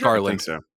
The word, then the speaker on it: Garland: Because Garland, is that how you pronounce his name? – Yes, Garland.